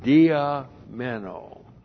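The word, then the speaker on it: dia meno (διαμένω): Then we have the verb dia meno. Dia meno. D-I-A-M-E-N, long O.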